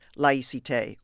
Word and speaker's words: laïcité: France actually has I guess you'd call it a policy called laïcité.